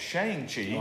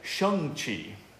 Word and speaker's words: Shang-Chi: And I didn't know I'd been mispronouncing that this whole time until Kevin Feige pronounced it properly. I've been calling it Shang-Chi. – Oh, I have too. – That's what everybody does, but it's actually Shang-Chi.